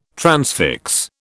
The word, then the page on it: transfix: British English